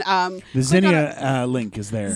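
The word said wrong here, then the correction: Zenni: Zenny, get the name right. Zennia. It's Zenny!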